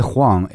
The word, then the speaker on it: Huang: And last name is Huang, H-U-A-N-G.